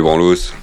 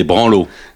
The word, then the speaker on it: branlos: Les branlos. Appelons-le les branlos. – Non, on dit des branlos. Il y a pas le, on prononce pas le S.